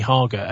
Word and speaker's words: Hager: Nicky Hager, H-A-G-E-R, pronounced like lager.